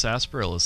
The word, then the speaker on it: sarsaparilla: I don't think I'm pronouncing correctly. – No, that's right. – It's pronounced sarsaparilla, Steven.